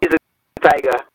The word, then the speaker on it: Izy Tiger: Okay, so it's Eyes a Tiger, correct? – Is a tiger.